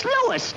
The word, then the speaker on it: Louis: Sounds like there might be a story at the plant, Lois. – Lois? Me name is Lewis, not Lois. Chief Wiz, everybody in Toy Palace me name wrong. It's Lewis. L-O-U-I-S.